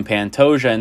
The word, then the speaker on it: Pantoja: Pantoja, there it is. Alexander Pantoja. That is the correct way to say his name. I was saying it wrong last pod.